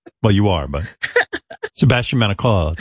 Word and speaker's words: Maniscalco: That was the closest you've come. – What's the matter with me? Maniscalco. – Maniscalco. – There you go.